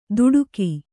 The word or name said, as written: ದುಡುಕಿ